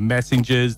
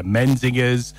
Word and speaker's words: Menzingers: And one of the hardest bands that I find to pronounce their name, it's spelt M-E-N-Z-I-N-G-E-R-S. I'll call them the Messengers, the Menzingers, all sorts of different things. Let's go for the Menzingers.